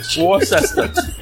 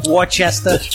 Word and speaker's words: Worcester: Worcester. – Yeah, Worcester.